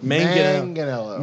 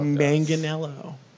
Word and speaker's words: Manganiello: Joe Manganiello? – Closer. It's Manganiello. – Manganiello. – Manganiello. – Oh, that's right.